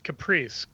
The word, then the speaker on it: Kupris: So it's spelled K-U-P-R-I-S, and so everyone says Kupris. But it's actually pronounced Caprice, because it's like Latvian or Lithuanian or something.